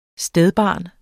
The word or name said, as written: stedbarn